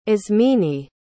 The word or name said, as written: ismene